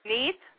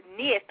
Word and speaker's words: Neith: But nobody is talking about Goddess Neith, or Nit, as some people pronounce it.